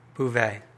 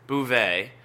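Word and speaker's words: Beauvais: How do you say it again? – Bouvet. – Bouvet.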